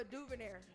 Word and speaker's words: DuVernay: I might pronounce her name wrong. Ava DuVernay. I think that's how you say it. – Ava. – DuVernay. Ava DuVernay. Okay, got it. – Ava DuVernay. – There you go.